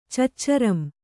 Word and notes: ಚಚ್ಚರಂ: caccaram

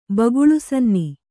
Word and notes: ಬಗುಳುಸನ್ನಿ: baguḷu sanni

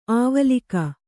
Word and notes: ಅವಲಕ್ಕಿ: avalakki

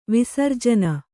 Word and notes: ವಿಸರ್ಜನ: Kannada; visarjana

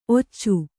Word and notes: ಔಚು: kannada

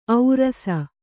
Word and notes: ಔರಸ: aurasa

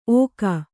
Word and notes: ಓಕ: ōka

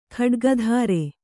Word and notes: ಖಡ್ಗಧಾರೆ: khaḍgadhāre

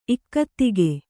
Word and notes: ಇಕ್ಕತ್ತಿಗೆ: ikkattige; kannada

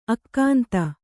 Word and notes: ಅಗುಂತಿ: Kannada

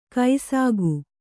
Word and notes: ಕೈಸಾಗು: kai sāgu

